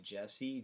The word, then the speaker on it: Jesse: Boom, you guys can hit me up everywhere at DJ Jesse, J-E-S-S-E.